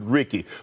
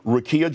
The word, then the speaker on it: Rekia: But last night on my podcast, Nightcap with Ocho and I mispronounced Rekia Jackson. I said Ricky. So I want to apologize to her personally. Rekia Jackson, congratulations to you being selected for number 4 overall to the LA Sparks.